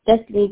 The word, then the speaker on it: Destiny: Destiny Davis. – Can you spell that for me? – Destiny, D-E-S-T-I-N-Y, Davis, D-A-V-I-S.